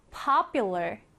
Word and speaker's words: popular: 자, 이 단어에서 강세부터 살펴보면 맨 앞에 이렇게 강세가 들어갑니다. 그래서 popular, popular 이라고 발음을 하고요.